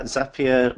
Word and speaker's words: Zapier: And Glenn tells me that Zapier rhymes with happier.